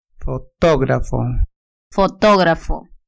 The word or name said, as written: fotografo